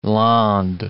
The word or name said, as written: Landes